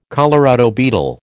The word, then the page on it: Colorado beetle: American English